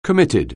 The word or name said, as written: commit